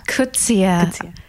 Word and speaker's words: Coetzee: Coetzee. – Coetzee.